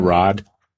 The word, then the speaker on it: Raad: R-A-A-D. What's the pronunciation on that? Rod? – Yeah, Rod.